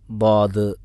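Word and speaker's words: ദാദ് (ضاد): ഈ ദാദ് എന്ന് പറയുമ്പോൾ നാവിൻ്റെ സൈഡിൽ നിന്ന് തന്നെ പറയാൻ നമ്മൾ ശ്രമിക്കണം. അങ്ങനെയാണ് അതിൻ്റെ ഉച്ചാരണം. നമ്മളെ നാവിൻ്റെ ഇടതു ഭാഗത്ത് നീളത്തിൽ ആ അണ്ണ മുകളിലത്തെ അണ്ണാക്കിനോട് ചേർന്ന് കൊണ്ടാണ് അത് ഉച്ചരിക്കുക.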